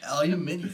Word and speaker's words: aluminium: Aluminium. – It's a valid way to pronounce it, for sure.